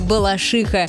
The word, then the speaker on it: Балашиха: Ну, конечно же, наша любимая Балашиха. Ударение в этом слове правильно ставить на последний слог.